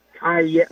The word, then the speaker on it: Kaye: Chad, his name is actually pronounced Kaye, as in Yippee-Ki-Yay.